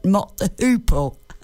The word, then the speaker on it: Mott the Hoople: Coming up after a word from Adam Simmons, Honolulu Boogie by Mott Hootle. Did I say that right? Mott the Hoople.